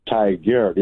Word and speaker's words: Tigard: it almost sounds like tiger, but it's Tigard. It's like T-I-G-A-R-D.